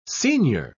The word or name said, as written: senior